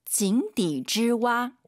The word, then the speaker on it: 井底之蛙: 井底之蛙.